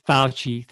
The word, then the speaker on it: Fauci: Fauci, thank you.